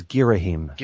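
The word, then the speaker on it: Ghirahim: That, uh, that gives me a nice segue into talking about the controls because the battles with Girahim is that how you say his name? – It's H-I-M. I think it's Girahim.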